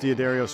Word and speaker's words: D'Addario: For my whole career, I've used D'Addario strings.